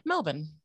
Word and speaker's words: Melbourne: So you're not going to put that emphasis on the O-U-R. It's just Melbourne.